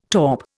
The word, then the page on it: taupe: Indian